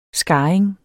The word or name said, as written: skarring